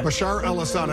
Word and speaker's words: Bashar al-Assad: Bashar al-Assad.